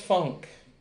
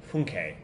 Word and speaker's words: Funke: So, Tobias Funk. – So Billy was from Arrested Development, and it's actually pronounced Funke. – Funke, yes. – Right, Funke.